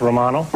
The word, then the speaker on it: Romano: Romano, pronounced Romano.